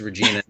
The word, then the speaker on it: Regina: Yeah, no, I told you, Regina, it rhymes with.